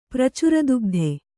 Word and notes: ಪ್ರಚುರದುಗ್ಧೆ: Kannada

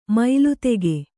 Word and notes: ಮೈಲಿತೆಗೆ: maili tege

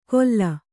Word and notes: ಗೊಲ್ಲ: Kannada

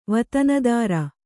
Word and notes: ವತನದಾರ: vatanadāra

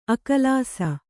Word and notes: ಅಕಲಾಸ: akalāsa

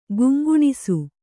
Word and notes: ಗುಂಗುಣಿಸು: guŋguṇisu

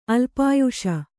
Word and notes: ಅಲ್ಪಾಯುಷ: alpāyuṣa

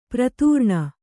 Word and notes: ಪ್ರತಾರಣ: pratāraṇa

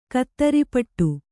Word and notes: ಕಾತರಪಡು: kātarapaḍu

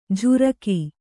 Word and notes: ಝುರಕಿ: jhuraki

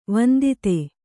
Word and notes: ವಂದಿತ: vandita

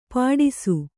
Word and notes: ಪಾಟಿಸು: Kannada

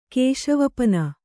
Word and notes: ಕೇಶವಪನ: Kannada

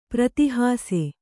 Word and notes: ಪ್ರತೀಹಾಸ: pratīhāsa

